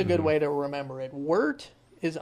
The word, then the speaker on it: wort: It's the liquid that you have in the sink Once you steep that first bag of grains, that becomes wort. – It is wort. – That word wart in your recipe. – It's pronounced wart.